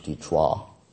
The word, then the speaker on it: Detroit: and this is in Detroit, or as they say in French, Detroit. So, that's actually the original pronunciation.